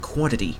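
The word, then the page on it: quantity: Canadian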